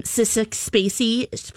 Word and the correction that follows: Sissy Spacek: You mean Sissy Spacek? – Sissick Spacey, roll call.